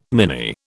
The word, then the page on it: mini: British English